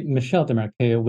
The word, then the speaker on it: Michel Desmarquet: His name is actually Michel Desmarquet when it's pronounced in French.